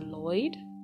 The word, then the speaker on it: Loid: Uh, Loid. Lo- is it Loid? It's Loid.